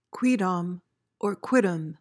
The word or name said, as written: quidam